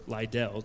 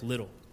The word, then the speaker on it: Liddell: It was a biographical message preached by David Campbell about Eric Little, or Liddell, depending how you pronounce it, but it's Eric Little.